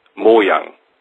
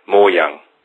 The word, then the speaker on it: Mojang: The right pronunciation of Mojang, like you say, is Mojang. So it's a short M-O and a Yang, like in Yin and Yang. Mojang. Without an A, more an L.